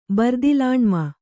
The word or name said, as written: ಬರ್ದಿಲಾಣ್ಮ